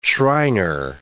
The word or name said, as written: Shriner